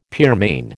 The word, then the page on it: pearmain: American English